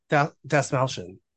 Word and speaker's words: Dastmalchian: Dastmalchian, I believe, is how you say it.